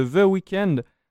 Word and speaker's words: The Weeknd: Donc, le 20 mars, vous pourrez découvrir le nouvel album de The Weeknd, After Hours.